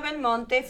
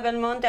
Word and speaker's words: Belmonte: It's S-I-L-V-I-N-A-B-E-L-M-O-N-T-E. See, I'm American. So we know Del Monte, she's Belmonte.